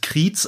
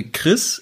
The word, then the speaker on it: Chriez: Und zwar ähm, C-H-R-I-E-Z das ähm, ist so sein Markenzeichen. Ähm, jeder Normaler würde das Kriez aussprechen, aber er, er hat es eingedeutscht. Er sagt äh, Chris.